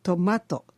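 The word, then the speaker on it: tomato: と例えばトマト、野菜のトマトですが、エスペラントでもトマトと言いますが、音節の数はトマトと 3 つです。後ろから 2 番目の母音の位置はマのところにあるので、トマトとマを強く発音します。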